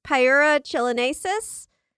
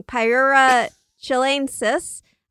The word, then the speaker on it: Pyura chilenensis: So this like leprous looking gray avocado is a tunicate called Pyura chilenensis. – What? – Wait. Sorry. I pronounced that totally wrong. Pyura chilenensis.